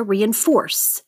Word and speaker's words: reinforce: Cash, clarification, despondent, embezzle, heart rending, leisurely, lethargic, malady, mellow, nomadic, piecemeal, quest, random, rant, reinforce, that's the third syllable there, reinforce, seclusion, status, turmoil.